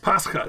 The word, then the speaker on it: Pascha: And then, because the Latin was Pascha, is that how it's pronounced? – Pascha, it's Greek, actually, originally.